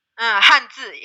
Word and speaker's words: hanzi: or tell me if I'm pronouncing this right, hanzi. – Hanzi, yeah, correct.